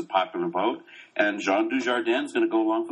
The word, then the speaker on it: Dujardin: I've been saying Dujardin for ages now. I just think he's the kind of guy they're going to go with. And you're finally pronouncing it correctly.